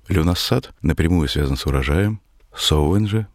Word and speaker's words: Samhain: Пишется и известен у нас под названием Samhain, но на самом деле произносится как Sowen.